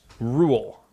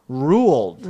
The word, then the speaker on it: Roald: It's actually pronounced Rule. – Ruled. – Ruled, though. – Yeah, the D is silent.